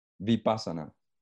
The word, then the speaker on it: vipassana: So this vinyasa is the way you pronounce it, vinyasa? – Vipassana. – Oh, vipassana.